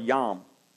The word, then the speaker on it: yom: The Hebrew word for day is the word yom, Y-O-M, or it's pronounced that way. I don't know how it's spelled, but yom.